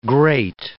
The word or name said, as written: grate